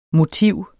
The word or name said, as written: motiv